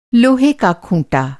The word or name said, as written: खूँटा